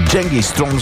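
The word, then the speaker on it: Genghis Tron: Genghis Throng всъщност е истинското им произнасяне, произношение.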